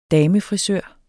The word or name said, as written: damefrisør